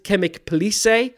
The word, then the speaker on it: Chemik Police: It's called Chemik Police, but it's spelled Chemik Police.